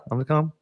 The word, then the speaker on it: Omicron: And, you know, because Omicron and all of this, I'm saying that right? Omicron? Yeah, Omicron. Anyway. – Omicron, I think.